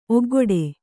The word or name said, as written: ಒಗ್ಗೊಡೆ